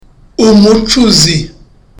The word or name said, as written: umucuzi